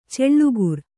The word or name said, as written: ಛಲಗಾರ